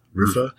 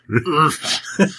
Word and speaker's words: RPHA: I need a better way to pronounce this helmet. RPHA. – Rufa? – Oh, yeah, you know what it is? It's pronounced like the noise like a fat guy makes when he picks something up from the ground.